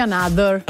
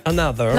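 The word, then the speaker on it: Anotr: E invece loro vogliono essere chiamati another. – Another. – L'hanno proprio detto, chiamateci another, anche se si scrive anotro.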